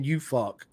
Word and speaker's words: Ufuk: And then we got my man, UFUCK. I know it sounds right, but it's spelled UFUK, right?